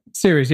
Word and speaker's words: Ceres: Ceres. Is that how you say it, Professor? – Ceres, right? – Ceres, yeah.